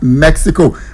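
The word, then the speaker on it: México: All right, so let's let you know about the World Cup, the FIFA World Cup happening in three countries: USA, Canada, Mexico. Mexicans call their country Mexico. We want to pronounce the X. They pronounce X like H.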